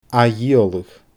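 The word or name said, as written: ath-dhìoladh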